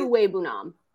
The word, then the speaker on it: Okwuegbunam: Is it Okwuegbunam? Is that what you said? – Okwuegbunam. – Okwuegbunam. – Yeah, it's honestly, when you look at it, it sounds really lame, but it's exactly how it's spelled.